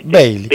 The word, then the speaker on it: Beale: Quiero ser amigo de John, se escribe Beale, Bailey o Bear, ¿cómo se dice? – Beale. Sería Beale.